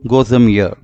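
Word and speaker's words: gozem ear (глазомер): The Russian word I am going to share today is pronounced gozem ear and literally translates to eye measurement.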